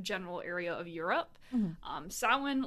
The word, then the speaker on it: Samhain: I think a lot of people I've heard this pronounce Samhain. Ooh. That's how it's spelled, but that's not how it fucking is. It is, I'm going to call it Samhain.